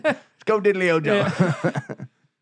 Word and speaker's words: Orgeron: We Tigers, Coach Ed Orgeron. Chicken on a stick. Look, tough breaks for them. They had some drops down the stretch. Nope. – Can we just say this right now? It is fucking Orgeron. – Yeah. – O-R-G-E-R-O-N. O-R-G-E-R-O-N.